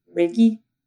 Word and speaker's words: Reiki: And so now my next endeavor is, and I don't even know if I say it correctly, Reiki.